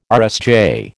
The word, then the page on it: RSJ: American English